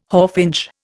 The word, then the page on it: hawfinch: Indian